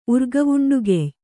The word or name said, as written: ಊರ್ಗಾವುಂಡುಗೆಯ್